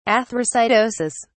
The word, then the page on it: athrocytosis: British English